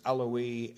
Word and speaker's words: Eloi: It's actually, I should say, the I is usually pronounced E. We just don't do that in English, but it's Eloi, Eloi, lama sabachthani. But the translation of that, it tells us right here, being interpreted, my God, why hast thou forsaken me?